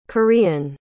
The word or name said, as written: korean